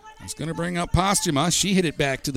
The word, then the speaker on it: Pastuma: It's spelled P-A-S-T-U-M-A, but I was told to say Postuma, like it was pasta and ma.